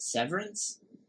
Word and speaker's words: Severance: Is it Severance? – Yeah.